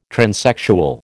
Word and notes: transsexual: American English

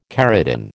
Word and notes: keratin: American English

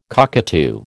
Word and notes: cockatoo: American English

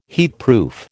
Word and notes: heatproof: American English

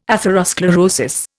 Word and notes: atherosclerosis: Indian

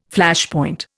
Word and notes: Flashpoint: Indian

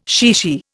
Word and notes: chichi: Indian

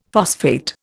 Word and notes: phosphate: Indian

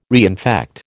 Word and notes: reinfect: American English

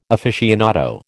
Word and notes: aficionado: American English

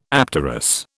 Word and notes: apterous: British English

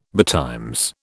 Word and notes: betimes: British English

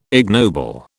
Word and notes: ignoble: British English

